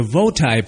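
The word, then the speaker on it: VOTI: I belong to the Votai Party. That's V-O-T-I. Votai rhymes with bow tie, which stands for Vote Out the Incumbent.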